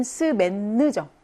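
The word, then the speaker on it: semaine: 그 다음에 한 주, 그 하나의 월화수목금토, 이렇게 주를 말하는 단어는 스맨느죠. 스맹이 아니고 스맨느.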